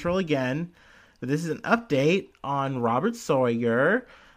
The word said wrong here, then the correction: Sarver: or Sarver. Gosh, so was I saying his name wrong in the other episode? Robert Sarver. Sorry. Like server, but Sarver.